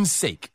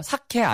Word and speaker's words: sake: 아, for one's own sake. – 네, for one's own sake예요. S-A-K-E. 이거 발음 잘하셔야 돼요. 사케 아니에요. 여러분, 내가 아침을 살게, 점심을 살게. 그 사케 아니고요. 네, sake예요. Sake. – S-A-K-E, sake.